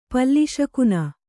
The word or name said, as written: ಪಲ್ಲಿಶಕುನ